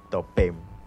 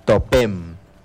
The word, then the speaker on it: topem: topem. Muy bien, vamos a pronunciarlo en lengua yocotán. En lengua yocotán se pronuncia topem.